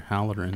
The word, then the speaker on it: Halloran: Mr. Halloran.